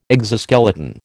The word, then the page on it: exoskeleton: American English